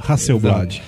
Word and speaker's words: Hasselblad: Hasselblad, Hasselblad é a pronúncia correta? – Hasselblad. Hasselblad.